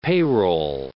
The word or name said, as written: payroll